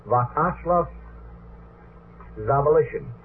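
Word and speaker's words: Vyacheslav Zawalischin: The gentleman's name, I hope I can handle it, Vakashlav Zavalishin. That's spelled V-Y-A-C-H-E-S-L-A-V. The last name is spelled Z-A-W-A-L-I-S-C-H-I-N.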